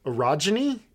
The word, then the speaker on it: orogeny: Demaya has been discovered to have orogeny. Is that how we're pronouncing this? – I think that's how you say it, yeah. – Okay, O-R-O-G-E-N-Y.